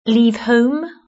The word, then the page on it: leave home: British English